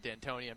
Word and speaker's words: D'Antoni: So we had D'Antonio and D'Antoni. I'm trying to keep my pronunciation straight here. But D'Antoni for the Rockets.